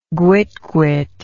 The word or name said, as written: guitguit